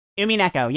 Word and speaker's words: Umineko: Is that how you pronounce it? – Umineko, yeah.